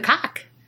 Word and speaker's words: Cocke: I mean, hello, because I love the cock. But it has an E on it, so it's not nasty. – Is that really how it's pronounced? – Yeah.